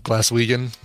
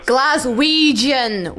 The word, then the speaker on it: Glaswegian: Glaswegian. Ouija-ja-ja-jan. Like Ouija board. Ouija-jan. – That's a soft G, not a hard G. She's yelling at us in the chat. It's a hard G. – It's a hard, yeah, that's a... Hold on, let me play that's it again. – Glaswegian. – So Ouija-jan is a hard G. – Ouija-jan is a soft G. – Or a soft G, Yeah. Glaswegian would be a hard G. – Yeah, so, you have it backwards.